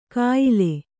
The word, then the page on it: ಕಾಯಿಲೆ: kāyile